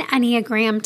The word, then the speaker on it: Enneagram: One of the things I love and I highly recommend doing is looking at the 9 Enneagram types. Enneagram is spelled E-N-N-E-A-G-R-A-M, Enneagram.